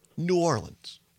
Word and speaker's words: New Orleans: She has corrected it. Here you have it, folks. The proper way to say it. The green and gold will play Monday, December 23rd, against New Orleans.